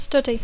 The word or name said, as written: study